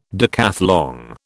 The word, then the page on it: decathlon: British English